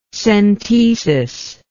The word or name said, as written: centesis